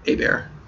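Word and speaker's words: Hebert: A-Bear, there was a guy in the NFL, which you spell Herbert, but they pronounce it A-Bear.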